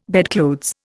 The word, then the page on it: bedclothes: Indian